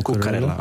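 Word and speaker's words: Cucurella: But in the game, also, I don't know how to pronounce his name. Is it Cucarella or Cucarella? – Cucarella.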